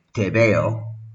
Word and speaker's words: tebeo: So it was enormously popular, and so much so that the word TBO, which really sounds like tebeo, that's how you pronounce those three letters quickly, and that also sounds like the sentence in Spanish, I see you.